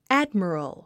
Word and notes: admiral: ǽdmərəl; アッドミロゥ

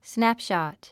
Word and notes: snapshot: snǽpʃɑ̀t; スナップシャト

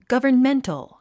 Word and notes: governmental: ɡʌ̀və r nméntl; ガバメンタル

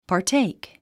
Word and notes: partake: pɑːrtéik; パーテイク

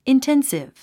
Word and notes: intensive: inténsiv; インテンスィブ

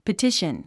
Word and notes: petition: pətíʃən; パティション

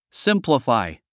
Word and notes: simplify: símpləfài; スィンプラファイ

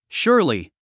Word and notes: surely: ʃúə r li; シュアリィ

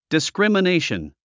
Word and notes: discrimination: diskrìmənéiʃən; ディスクリマネイション

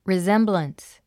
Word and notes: resemblance: rizémbləns; リゼンブランス

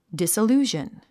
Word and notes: disillusion: dìsilúːʒən; ディサルゥジャン

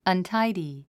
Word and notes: untidy: ʌntáidi; アンタイディ

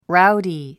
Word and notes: rowdy: ráudi; ラウディ

